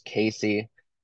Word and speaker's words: CACI: So it's actually pronounced Casey, uh, C A C I and it's during instruction 2521A.